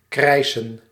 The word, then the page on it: crier: France; [kʁi.je]